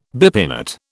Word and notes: bipinnate: British English

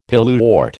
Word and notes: pilewort: American English

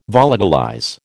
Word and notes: volatilize: American English

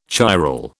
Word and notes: chiral: British English

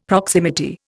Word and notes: proximity: Indian

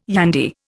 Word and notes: yandy: Indian

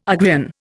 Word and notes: Ugrian: Indian

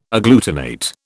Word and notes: agglutinate: British English

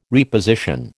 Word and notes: reposition: American English

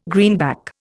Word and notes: greenback: Indian

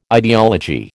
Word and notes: ideology: American English

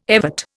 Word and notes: evert: Indian

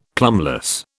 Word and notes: plumbless: British English

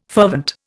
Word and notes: fervent: Indian

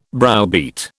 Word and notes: browbeat: British English

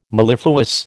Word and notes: mellifluous: American English

